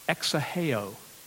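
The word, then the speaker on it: exaheo (ἐξηχέω): That phrase sounded forth, by the way, is, listen to how it's pronounced, exaheo is how you pronounce the Greek term that translates into sounded forth. It's where we get our root, our word, echo. Echo comes from this Greek term, exaheo, echo.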